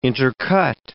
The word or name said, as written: intercut